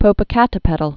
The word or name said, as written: Popocatepetl